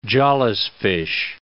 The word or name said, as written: jawless fish